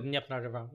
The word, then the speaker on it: Dnipro: I saw something recently that the Ukrainian forces have crossed the, now you correct my pronunciation, but the Dnipro River. – The Dnipro River, yeah.